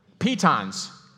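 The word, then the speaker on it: pitons: I think they're called Tetons. I hope I'm pronouncing it right. They am I pronouncing it right? Any mountain climbers here? Petons. Hold on, what? Petons. Okay, there we go.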